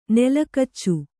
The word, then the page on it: ನೆಲಗಚ್ಚು: Kannada